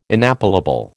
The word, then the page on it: inappellable: American English